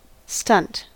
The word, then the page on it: stunt: American English